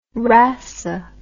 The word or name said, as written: rasse